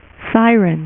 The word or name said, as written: Siryn